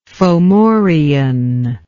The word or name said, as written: Fomorian